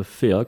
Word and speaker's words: PHEA: Alors aujourd'hui, j'ai trois petites entreprises qui constituent le groupe FEA, qui s'écrit P-H-E-A.